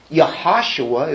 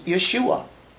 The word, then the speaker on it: Yeshua: Yahashua is the way it would be pronounced, or very close to Yeshua.